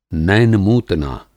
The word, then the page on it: नैनमूतना: नैन-मूतना